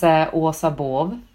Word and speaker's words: Asa Baav: My name is Orsa Borv. It's a very Swedish name, so even I find it difficult to pronounce nowadays. So go with Orsa.